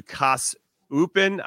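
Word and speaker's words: KAS Eupen: He's going to go on loan to Kas Upen. I don't know if I said that right, but I'm trying over here. – That sounds really right.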